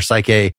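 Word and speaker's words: Psuche (ψυχή): Am I just right that these, whether it's pronounced Psuche or Psyche, Psyche is just a matter that they didn't really spell out their vowels or something? Like, there is a standard pronunciation that you learn as Psuche.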